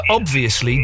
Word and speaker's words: obviously: Well, we are arguing here, we have been arguing, it's the debate that's on everyone's lips this evening, is obviously, do you pronounce the B in obviously? – Yes. – Of course definitely. You do, don't you, Toby? – Yeah.